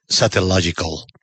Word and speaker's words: Satellogic: Esperemos a ver qué pasa cuando salga el satélite Merlin con Satellogical. Gracias. Dólares en Colombia. ¿Cómo se lo pronuncia? Satellogic. Me gusta.